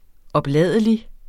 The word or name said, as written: opladelig